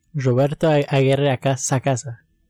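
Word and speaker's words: Roberto Aguirre-Sacasa: But I also wanted to touch on some tweets from, forgive me for this pronunciation, but Roberto Aguirre Sacasa? – Roberto Aguirre Sacasa.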